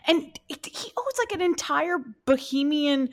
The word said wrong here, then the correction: Bahamian: Bahamian like island.